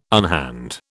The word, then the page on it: unhand: British English